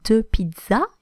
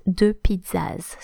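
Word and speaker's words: deux pizzas: We don't pronounce the final, the plural and final S in French. So when you say two pizzas, it will be de pizza and not de pizzas.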